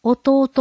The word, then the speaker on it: お堂: 漢字で御礼の御に当たると書いてお堂と読みます。